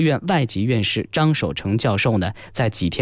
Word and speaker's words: 张守成: 张守成教授把这个字呢自己愿意读作成啊，所以呢我们在节目当中所称呼到的是张守成教授。